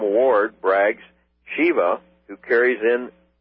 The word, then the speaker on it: Shiva: It is also intriguing to note that the god Shiva, which is a synonym for Satan, Shiva, that's how you say it.